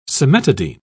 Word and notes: cimetidine: British English

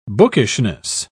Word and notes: bookishness: British English

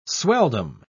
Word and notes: swelldom: British English

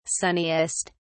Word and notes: sunniest: British English

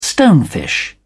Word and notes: stonefish: British English

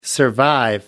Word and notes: survive: American English

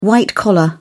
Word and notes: white-collar: British English

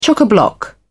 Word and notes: chock-a-block: British English

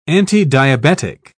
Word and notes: antidiabetic: British English

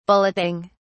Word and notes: bulleting: British English

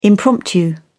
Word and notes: impromptu: British English

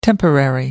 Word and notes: temporary: American English

